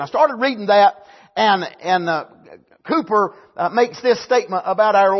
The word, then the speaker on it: Cowper: I found a book written by William Cowper. I looked it up to see, and according to the computer, the proper pronunciation is Cooper. I knew some people called him Cooper.